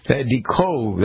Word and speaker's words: Decot: D-E-C-O-T is DeCotte. That would be how you'd pronounce it.